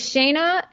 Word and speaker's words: Shana: It is Shana S621. So my name is spelled S-H-A-N-A, last name starts with an S, and then 621.